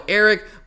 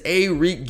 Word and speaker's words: Arik: UGA did send out their official pronunciation guide. I've heard players on the team, coaching the team, refer to him in different pronunciation. I've heard Kirby call him Arik. I've heard JT Daniels call him Eric, but officially it is Arik Gilbert, so that's what we're going with here.